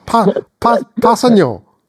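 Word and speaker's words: Kpassagnon: I've gone for Tano Capasagnon for the new Passagnon. – Silent K, silent G, and an additional Y. – Yeah, I believe it's like Passagnon, basically. Passagnon.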